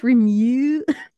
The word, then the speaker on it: remunerative: The reason why it's because, all right, the pronunciation that they give you has a Y randomly in mew. So it's like mew. You don't know how to say it. It's supposed to be, I guess, remunerative. This is a horrible word.